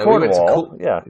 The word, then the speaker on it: Cornwall: I was looking forward to you try and pronounce Cornwall or whatever you call it. – No, Cornwall, that's very good.